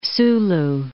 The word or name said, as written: Sulu Archipelago